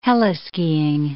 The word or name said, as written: heli-skiing